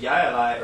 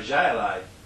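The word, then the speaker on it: jai alai: Anyway, Chayalai is from that, but chay is with a jota in Spanish, but it's pronounced Yayalai or Jayalai on the northern side, because they don't pronounce that jota sound the same in French.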